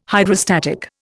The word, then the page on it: Hydrostatic: Indian